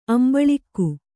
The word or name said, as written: ಅಂಬಳಿಕೆ